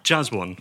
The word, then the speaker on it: Jaswon: As an expert, how would you say your name, Ivor? – Jaswan. – Jaswan. – That's how it comes out.